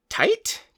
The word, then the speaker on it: Tait: We have I believe it's pronounced Tait, T-A-I-T, Fletcher, who was Paz Vizsla's performance artist.